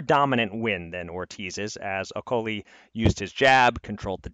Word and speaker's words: Okolie: I'm learning the correct pronunciation. It's Okolie, which is just devastating as someone who is enjoying making Oakley-Dokley jokes.